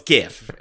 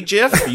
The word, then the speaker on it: GIF: Oh, it's GIF. And if you say JIF, you can go fuck yourself. – Yeah, you're a terrorist. – A soft G sound on a word that's spelled G-I-F?